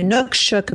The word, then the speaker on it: Inukshuk: And this is where he was talking about and pointing to, and I told you I'd find out how to say it, Inukshuk. So it's Inukshuk.